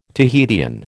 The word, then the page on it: Tahitian: American English